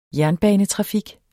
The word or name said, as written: jernbanetrafik